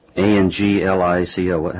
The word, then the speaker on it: ANGLICO: ANGLICO. How do you pronounce that? – Anglico.